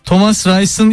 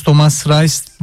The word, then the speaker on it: Thomas Reis: Thomas Rice'ın ismini yanlış söylüyorsunuz. E ile I, I olarak okunur. Okunuşu Thomas Rice. Thomas Reis Almanca'da bu şekilde okunur diyor dinleyicimiz.